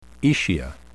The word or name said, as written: aecia